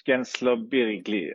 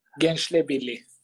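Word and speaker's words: Gençlerbirliği: Alltså, min, mitt försök är eh, Gen, Genslobirgli. Nej, det går inte. Hur säger man det? Genslebirgli. Okej.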